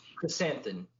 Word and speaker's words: Krrsantan: Krrsantan.